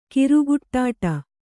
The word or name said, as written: ಕರಿಗಿಡ್ಡ